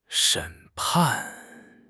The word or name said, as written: 审判